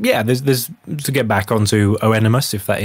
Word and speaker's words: Oenomaus: It's one of those Greek spellings, and it's Oenomaus. So Oenomaus, I think, is how you pronounce it.